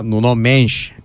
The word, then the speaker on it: Nuno Mendes: Bu arada Nuno Mendes telaffuzu doğru oldu. Selim Evrengil tarafından doğrulandı. Yani Brezilya'dan onay aldık.